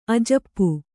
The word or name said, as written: ಅಜಪೆ